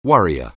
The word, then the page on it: worrier: anglais